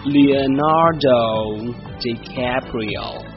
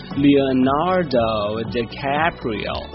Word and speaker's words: Leonardo DiCaprio: 原来是因为翻译的原因，他的名字的原名呢是念作，来和我一起念作 Leonardo DiCaprio。再来一遍 Leonardo DiCaprio。